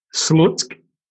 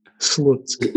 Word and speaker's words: Slutsk: But how do you pronounce that? – Slutsk. – Right. – Slutsk. – Is it 1K? – No, Slutsk.